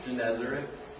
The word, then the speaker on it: Gennesaret: Anybody want to pronounce that for me? – Gennesaret. – Thank you.